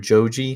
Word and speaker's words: Joji: There's, you familiar with Joji? Is that how you say his name? – Yes.